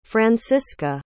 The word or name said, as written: franziska